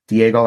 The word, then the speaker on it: Diego: Diego like San Diego. And no one ever gets my last name right, so I'll spell it L-O-M-A-N-T-O.